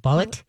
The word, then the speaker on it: balut: It's called, I think I'm spelling it right, or saying it right, bullet. B-A-L-U-T. Bullet.